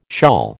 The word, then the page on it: shawl: American English